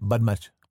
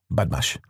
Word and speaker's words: Badmash: It's pronounced Badmash. – Badmash? – Badmash. – Okay, you have the accent.